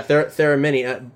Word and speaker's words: Theremini: Yeah, Theremini.